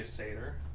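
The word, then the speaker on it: Saiter: Was she a Sater? – I don't even know if that's how you say it, but. – Is it S-A-T-Y-R? – No, it's S-A-I-T-E-R. – Sider? Sater? – Yeah, exactly.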